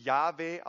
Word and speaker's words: Jahwe: Wir dachten erst Jehova, mittlerweile gehen wir davon aus, dass es wahrscheinlich Jahwe ausgesprochen wurde, Jahwe, aber wir wissen es nicht.